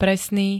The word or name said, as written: presný